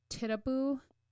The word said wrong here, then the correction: Tituba: It's Tituba.